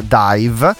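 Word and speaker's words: DIIV: chiama The Fountain, questo brano che abbiamo appena ascoltato, ed è il nuovo singolo dei Dive, band newyorkese il cui nome non si scrive D-I-V-E come forse potrebbe venire spontaneo pensare con la pronuncia Dive, ma in realtà è D-I-I-V.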